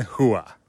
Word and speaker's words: Rua: Mauricio Shogun Hua. – It's a silent R, right? – It's an H. – We've got silent R's. – It's an H in Portugal. It's Portuguese. It's an H. R's an H.